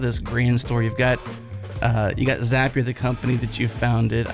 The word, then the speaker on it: Zapier: And Zapier makes you happier, right, Bryan? – That's correct. – I heard that once, right? It's a fun thing. – Yeah, it helps you pronunciate it correct.